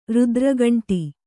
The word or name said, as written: ರುದ್ರಗಂಟು